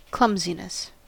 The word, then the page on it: clumsiness: American English